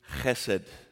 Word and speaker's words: chesed: And the Hebrew word is hesed. H-E-S-E-D is how it's transliterated. But I think it's fun to say, if you use like the Hebrew, chesed is how you say it, okay? So I want us all to say it on the count of three. Ready? Can you use that back of the throat noise?